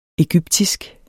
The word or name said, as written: egyptisk